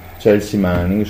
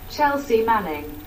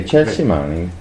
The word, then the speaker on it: Chelsea Manning: Va bene, procediamo, al di là di questo elemento triste rispetto a Chelsea Manning, scusami, sì. Il fatto che non so pronunciarlo correttamente. Scusate. E vabbè, prego XY. – Arrivo, un attimo. – Chelsea Manning. – Okay, Chelsea Manning.